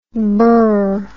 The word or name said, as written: Broz